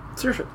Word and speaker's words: Saoirse: My quick internet look says her name is pronounced Saoirse or Saoirse. – Saoirse.